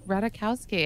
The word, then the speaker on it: Ratajkowski: I guess I didn't say it right. Emily Ratajkowski. No. How do you say it? Ratajkowski? – Ratajkowski, yeah.